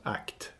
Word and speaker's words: ACT: Jag antar att det uttalas ACT, alltså ACT som att agera, fast på engelska.